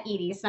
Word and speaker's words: Iris: Or it's said Iris, not spelled, but. – Interesting. – Yeah, it's because, you know, in Spanish, the I is pronounced like an E,